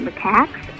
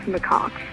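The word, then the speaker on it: macaque: I was catching up on some shows I missed, and on the January 4th show, you mentioned the cheap monkey whores, and you kept pronouncing it macax, but it's actually pronounced macaques.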